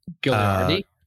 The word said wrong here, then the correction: Guaraldi: Gagliardi. – Guaraldi. – Guaraldi. Yeah, I always miss.